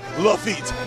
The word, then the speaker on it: Lafitte: Just like pig feet, it rhymes with the pirate Lafitte.